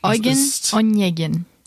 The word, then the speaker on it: Eugen Onegin: Eugen und Jecken. – Eugen. Aha. Ich kann das nicht aussprechen. – Eugen und Jecken. – Eugen Onegin.